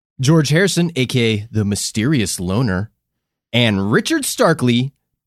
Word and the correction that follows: Starkey: better known in Japan. I mispronounced that already. Already I cannot read, listeners. Let's take that back. And Richard Starkey, better known in Japan as Ringu.